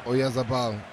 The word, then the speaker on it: Oyarzabal: Oyarzabal. – Yeah. Oyarzabal giving all that he has, getting that goal.